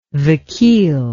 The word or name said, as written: vakeel